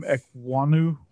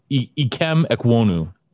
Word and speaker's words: Ekwonu: I really like Ikem Ekwonu. – Yes. – Did I say that right? – It's Ikem Ekwonu. – Ikem Ekwonu. – Ekwonu.